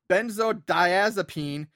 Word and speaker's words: Benzodiazepine: Benzodiazepine.